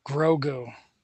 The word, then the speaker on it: Grogu: Pronounce it. So close. Say it again. – Goru? – Grogu. – Grogu. Ah, yeah. – Damn, dude. – Was close, not quite though.